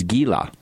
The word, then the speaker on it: Gila: Now, of course, the correct pronunciation is Gila.